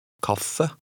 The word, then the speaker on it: kaffe: Norwegian words are normally stressed on the first syllable. With this in mind, read number 12. – Kaffe.